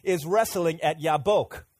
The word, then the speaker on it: Yabok: Yeah, that's how we say it, Jabbok. But in Hebrew, here's how they say it. They say Yabok. Okay, let's say that. 1, 2, 3. Yabok.